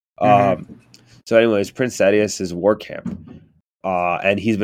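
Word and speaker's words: Sadeas: So anyways, chapter 6, Kaladin arrives at Prince Sidious. Sidious? Is that how you pronounce it? – God dang it. Sadius, I think. – Sadius? – I think it's Sadius.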